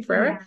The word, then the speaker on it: Ferrera: And I'm like, of course, because you know, when America Ferrera, is that how you say her name, Ferrera? – Yeah.